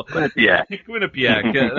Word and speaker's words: Quinnipiac: I've been practicing on how to pronounce this correctly. Quinnipiac. terrible. Quinnipiac. That's right.